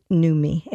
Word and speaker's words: Numi: The first one is from Kohler, and it's the Kohler, I think you pronounce it Numi, N-U-M-I.